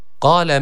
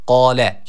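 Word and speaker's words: Kale (قال): Kale men yuhyil izame ve hiye ramim. – Kale kelimesi malum hatırlayacaksınız. Kale şeklinde sıkça okunuyor. Yanlış bir okuyuştur. Dikkat edelim, kaf harfi var. – Kale.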